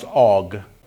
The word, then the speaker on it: Og: And then there's Og, which is actually pronounced Og, like an A-W-G.